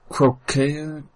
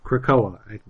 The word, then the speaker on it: Krakoa: Krakoa, I believe, is the pronunciation.